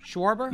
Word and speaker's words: Schwarber: Yeah, he's on the Schwarber? – Nationals now. – Schwarber.